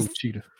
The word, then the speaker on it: Ouachita: Yeah, Ochi- like Ouachita Baptist. I may be butchering that. Sounds like somewhere in Mississippi. – Well, you better be O Jaguar Baptist now. it's, Ouachita.